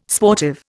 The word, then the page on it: sportive: Indian